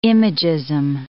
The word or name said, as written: imagism